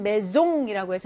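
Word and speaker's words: maison: 4번에는 메종이라고 해서 얘는 즈 발음이죠. S가 모음과 모음 중간에 들어가게 되면 즈 발음이 나는 거 기억나실 거예요. 그래서 메종이 됩니다.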